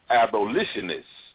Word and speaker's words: abolitionist: I learned about it in ammunitionist in Mr. Douglas's class. They were like the pilgrims. They hated slavery. – Yeah, good job, LaShawn. Only it's pronounced abolitionist.